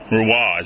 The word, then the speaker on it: Rouen: Now, how do you say that city in France? R-O-U-E-N? – Rouen. – Rouen. Is that how you say it? – Rouen. – That's him? – Rouen. – So I've been mispronouncing it every time I've read it in the book. – it's an O-N-Rouen.